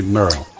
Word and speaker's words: Merle: Vamos a escuchar una, una canción norteamericana, 16 toneladas, 16 Eh, creo que es un tal, yo lo digo escrito, eh, Merle, no sé cómo se pronunciaría. – Sí, Merle.